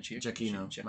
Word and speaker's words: Giacchino: if I was Giacchino... Did I say that right? – Giacchino. Michael Giacchino. You're my boy.